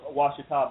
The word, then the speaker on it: Ouachita: I guess you'd be the pride of Ouachita Baptist University, correct? – Yes, sir.